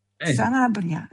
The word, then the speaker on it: Sanabria: forse questo si dovrebbe, dovrebbero fare i nostri ascoltatori, vedere dove, perché ieri in campo c'era nel Paraguay Antonio, ora vediamo che lo pronuncio bene, Canabria? – Sanabria, sì, che è della Cremonese.